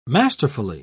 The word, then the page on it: masterfully: British English